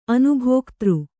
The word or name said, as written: ಅನುಭೋಗದಾರ